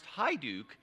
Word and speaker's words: Hajduk: My name is Steve Hajduk, which is spelled Hey Duck, but now that you've heard it's pronounced Hajduk, if you say it Hey Duck, on a day that we're talking about hospitality, if I notice that you say it wrong, I might not feel very welcomed.